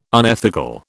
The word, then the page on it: unethical: British English